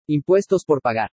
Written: IMPUESTOS POR PAGAR

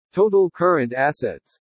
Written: TOTAL CURRENT ASSETS

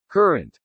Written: CURRENT

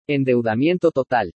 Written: ENDEUDAMIENTO TOTAL

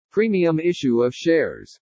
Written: PREMIUM ISSUE OF SHARES

